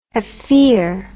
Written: haffir

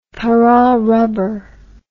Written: pararubber

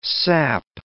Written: SAP

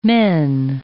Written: men

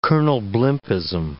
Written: Colonel Blimpism

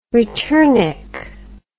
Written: returnik

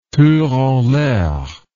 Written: tour en l'air

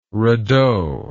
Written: radeau

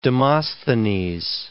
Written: Demosthenes

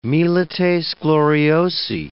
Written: milites gloriosi

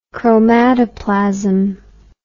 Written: chromatoplasm

